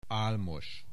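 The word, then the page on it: Álmos: Hungarian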